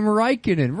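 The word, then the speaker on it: Räikkönen: Yeah, that was super fun when we played Celebrity and none of you could pronounce the name Raikkonen. Rhymes with Vikenin.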